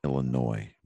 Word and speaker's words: Illinois: And just so that you know, it's pronounced Illinois. The S is silent.